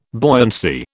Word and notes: buoyancy: British English

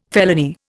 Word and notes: felony: Indian

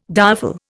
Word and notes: direful: Indian

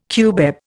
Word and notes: cubeb: Indian

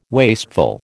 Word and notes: wasteful: American English

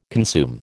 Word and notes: consume: American English

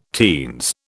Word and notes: teens: British English

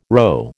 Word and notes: rho: American English

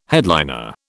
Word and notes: headliner: British English

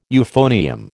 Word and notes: euphonium: American English

